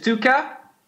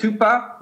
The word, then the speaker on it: tuka: So tuka is the drum beat, you know, going tuka, tuka, tuka, tuka, with a K, which is important because people pronounce it tupa, but tupa doesn't have the same attack as tuka.